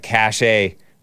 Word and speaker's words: cachet: I think it's pronounced cash, right? Cash. It's not cache. FYI, Leland Vittert. When you're talking about cash, he's talking about Taylor Swift has a lot of cachet with the,